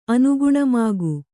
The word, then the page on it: ಅನುಗುಣಮಾಗು: Kannada